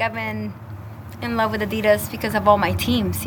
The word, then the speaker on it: Adidas: and the big three-stripe gorilla in the room is three syllables, Adidas.